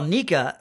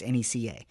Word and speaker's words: NECA: Well, Nika, who I have been told is supposed to be pronounced NECA, because it stands for Northeastern Collectible Association.